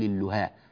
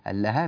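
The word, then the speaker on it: اللهاة: ولاحظوا أقول أنا اللهاه ولا أقول اللهاه، اللهاه بفتح اللام كذلك هي التسمية أو النطق الصحيح لهذه الكلمة.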